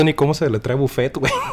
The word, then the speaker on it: buffet: Mi primer jale fue en un lugar de buffet, este, para los de Conalet, buffet es este una palabra en francés.